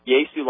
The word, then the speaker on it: Ieso: Sound familiar? I-E-S-O, Yesu, is pronounced Yesu like Yeshu, except without the H, since the Greek language has no H sound in its alphabet.